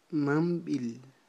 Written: mamb'il

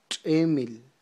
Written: tx'emil

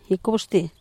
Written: gicub xtily

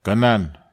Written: kanan

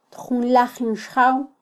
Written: tjunlajin xjaw

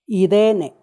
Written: idene

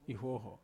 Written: huoho

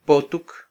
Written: potuk

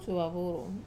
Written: suovavuru

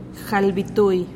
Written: jalbitui

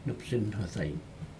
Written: nuptsin hodig

